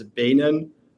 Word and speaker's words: Beenen: Beenen, right? Niels, well, you can say Beenen in Dutch. I'm from the Netherlands. It's Beenen.